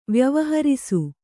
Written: ವ್ಯವಹರಿಸು

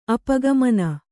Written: ಅಪಗಮನ

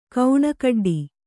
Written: ಕೌಣಕಡ್ಡಿ

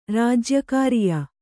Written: ರಾಜ್ಯಕಾರಿಯ

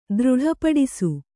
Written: ದೃಢಪಡಿಸು

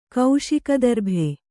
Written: ಕೌಶಿಕದರ್ಭೆ

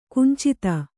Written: ಕಿಂಚಿತ್ತು

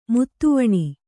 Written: ಮುದಿವೆಣ್

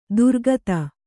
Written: ದೂರಗತ

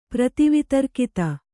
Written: ಪ್ರತಿವಿತರ್ಕಿತ

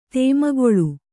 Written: ದಮ್ಮುಗೊಳ್ಳು

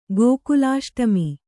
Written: ಗೋಕುಲಾಷ್ಟಮಿ

